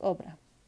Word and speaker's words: Oprah: Ofra Winfrey, oh, Oprah, lo dije mal chicos, Oprah.